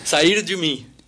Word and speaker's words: Sair de Mim: Sair de Mim. Bueno, sí. Solo que lo he pronunciado fatal. – Sair de mí.